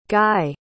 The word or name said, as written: Gae